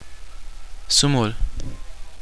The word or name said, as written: twenty